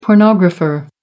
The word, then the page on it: pornographer: American English